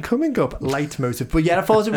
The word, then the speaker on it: leitmotiv: So it's a German phrase. It's pronounced leitmotiv, but it's not spelled it.